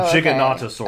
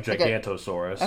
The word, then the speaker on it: Giganotosaurus: It's Gigantotosaurus. – Oh, or Giganotosaurus. – Okay. – I'm trying to pronounce it differently. It kind of looks like Gigantosaurus.